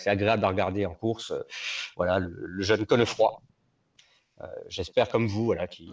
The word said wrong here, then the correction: Cosnefroid: Cosnefroid, il faut prononcer Il est AG2R.